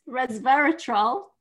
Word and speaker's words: resveratrol: That's not how you pronounce it. Reserve res, resveratrol, which is in red wine, is really good for your heart.